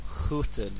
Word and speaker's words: groeten: Groeten. – Okay, so iemand groeten.